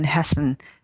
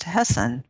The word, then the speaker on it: Hesse: By the way, I don't know if you know this, but I've said Hermann Hess or Hesse all my life. Like I've never looked up how do you pronounce Hermann Hesse's last name, and it's pronounced Hessen with sounds like an N, but there's no N there.